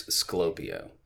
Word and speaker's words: Sclopio: You said Slopio, I think. It's Sclopio.